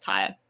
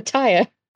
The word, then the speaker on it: Tyre: I think it's pronounced Tyre. – Is that T-Y-R? – T-Y-R-E. – Oh, like a tire. – Yeah, like tire.